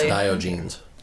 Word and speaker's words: Diogenes: I'm pretty sure no, that's pronounced Diogenes.